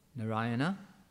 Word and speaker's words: Narayana: One of them is na, that, so there's four syllables in there, and then we put them all together as Narayan. Narayana, Narayana is the form of the Lord when he's in the spiritual world and in the kingdom of God.